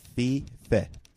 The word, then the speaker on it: Fiffe: F-I-F-F-E gave me an enunciation of fee-fe. – Fee-fe? – F-E-E-F-E-H. – Rhymes with meh, your favorite word?